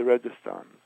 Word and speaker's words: Registan: But in Samarkand, uh, you must must go to the Registan, the R E G I S T A N. I think they spell it Registan, uh, the mausoleum.